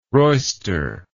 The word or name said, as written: royster